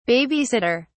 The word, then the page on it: baby sitter: British English